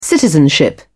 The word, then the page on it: citizenship: British English